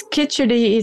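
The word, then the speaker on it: kitchari: It's Kidgeri. It's pronounced in a special way. I have to tilt your tongue to go to the back. It's actually a Hindi word.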